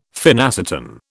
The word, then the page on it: phenacetin: British English